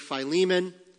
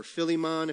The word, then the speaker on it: Philemon: You're going to go through Philemon, or Philemon if you say it wrong.